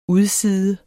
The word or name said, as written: udside